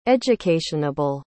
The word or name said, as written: educationable